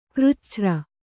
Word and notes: ಕೃಚ್ಛ್ರ: křcchra

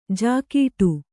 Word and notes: ಝಾಗಟಿ: Kannada

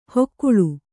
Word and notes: ಹೊಕ್ಕುಳು: hokkuḷu

